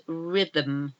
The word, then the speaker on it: rhythm: And the next one we have, the last one in the letter, silent letter H, is rhythm. Rhythm. R-H-Y-T-H-M.